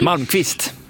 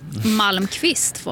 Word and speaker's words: Malmqvist: De ska ju ha lite kul också för folkkära Siv Malmqvist, om jag inte har uttalat fel. – Malmqvist. – Malmqvist var det.